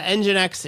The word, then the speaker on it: Nginx: You didn't have to manage your Nginx server or Nginx. Is Nginx a server? Is that a correct way to say that, Wes? – Yeah. Nginx is a web server that will handle incoming requests and do SSL certificates.